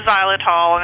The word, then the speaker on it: xylitol: No, it's strictly the xylitol, and it's, it's xylitol is spelled with an X, so it's X Y L I T O L, just so you know.